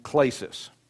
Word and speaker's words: klesis: It's spelled K-L-E-S-I-S, klesis.